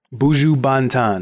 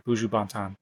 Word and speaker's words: Buju Banton: It's Buju Banton, right? – Buju Banton. – Banton. Thank you. Buju Banton.